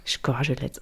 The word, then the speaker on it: Szczecin: Szczecin. – Ich hoffe, das war jetzt richtig so. – Szczecin, okay, das merken wir uns.